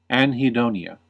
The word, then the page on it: anhedonia: American English